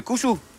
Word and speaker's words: Kuzu: U bent meneer Kuzu. – Dat klopt helemaal. – Spreek je dat goed uit? – Helemaal goed. In 1 keer.